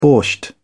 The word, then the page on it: borscht: British English